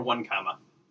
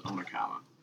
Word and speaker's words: Onekama: Or one comma. I, I, thought it was when I first moved up here, I thought it was Onekama. It got pronounced one comma one time on the uh, football selection playoff, yeah, playoff selection show.